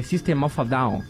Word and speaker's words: System of a Down: Lembrei daquela entrevista da Sandy, que ela fala que, que a pronúncia correta é System of a Down.